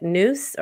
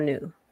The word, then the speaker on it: nous: Now, tell me how to say it. Noose or new? – Oh, noose. Yeah. N-O-U-S. – Yes. Uh-huh. Noose. Yes.